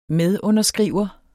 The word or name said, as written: medunderskriver